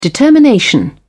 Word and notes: determination: British English

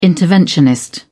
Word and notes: interventionist: British English

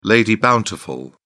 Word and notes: lady bountiful: British English

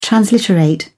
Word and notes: transliterate: British English

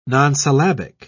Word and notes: nonsyllabic: British English

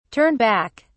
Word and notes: turn back: British English

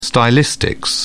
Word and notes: stylistics: British English

